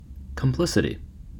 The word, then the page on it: complicity: American English